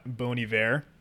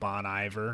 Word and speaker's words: Bon Iver: We got Bon Iver. Mm. Yes, that's how you say it. It's not Bon Iver or however else.